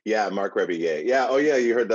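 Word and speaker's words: Rebillet: I always want to call him Mark Riblet, but that's not his last name. – McRiblet, it's back, guys. No, it's Rebeye.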